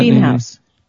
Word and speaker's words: Beamhaus: Beamhaus, B-E-A-M-H-A-U-S. – Does that Beamhouse.